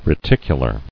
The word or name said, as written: Reticular